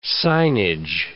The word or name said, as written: signage